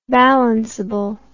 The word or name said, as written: balanceable